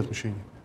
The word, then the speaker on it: отмщение: В данном случае, когда речь идет об отмщении, в Священном Писании сказано: «Мне отмщение, аз воздам». Именно с таким ударением, то есть «мне отмщение», «я могу мстить.